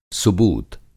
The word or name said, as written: सुबूत